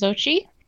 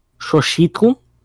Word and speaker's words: Xochitl: Yeah, it would have like a very heavy what's it called, glottal sound at the end of it, but it'd be Xochitl, I think it would be. – Oh. – Because the X would be pronounced like a CH, almost like a either CH or SH is what's debated.